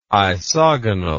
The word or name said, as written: isogonal